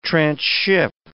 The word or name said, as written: transship